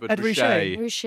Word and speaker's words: Ed Ruscha: Well, I think it's, I don't know how to say it, is it Ed Ruscha? – Yeah, Ed Ruscha.